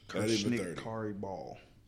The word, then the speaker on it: Kirsnick Khari Ball: It's just unfortunate that we lost another young, 28 years old, Kirsnick Khari Ball.